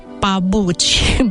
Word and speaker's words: pabuci: Unul dintre cuvinte este papuci, dar ei nu pronunță litera P, pronunță litera B și le zic pabuci, pabuci, papuci.